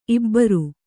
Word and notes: ಇಬ್ಬರ್: ibbar

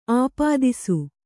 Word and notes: ಅಪದೆಸೆ: apadese

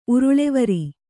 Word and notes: ಉರುಳಿವರಿ: uruḷivari

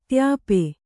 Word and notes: ತ್ಯಾಪೆ: tyāpe

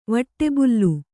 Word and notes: ವಟ್ಟೆಬುಲ್ಲು: vaṭṭebullu

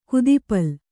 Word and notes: ಕುದುಪಲ್: kudupul

